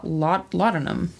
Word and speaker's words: laudanum: I said, what do you know about laudanum? That's how you say it.